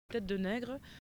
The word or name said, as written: negre